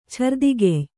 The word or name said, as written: ಛರ್ದಿಗೆಯ್